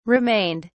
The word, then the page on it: remained: British English